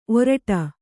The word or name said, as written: ಓರಿಡು